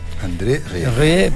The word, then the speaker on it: André Rieu: El 18 de octubre disfruta de todo el talento de Andrés, ¿cómo se pronuncia? – André Rieu.